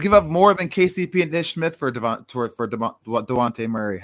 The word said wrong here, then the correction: Dejounte: DeJounte Murray? – Yeah.